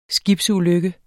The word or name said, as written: Skibsulykke